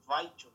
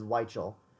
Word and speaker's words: Weichel: I thought his last name was pronounced with a V, as in Weichel. – No, I've always pronounced it as Weichel.